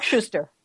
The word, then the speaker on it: Suster: is with an interview with Mark Suster, who has Suster. I'm so sorry, Mark. – Mark Schuster. – Schuster. Next time I'll know how to say it.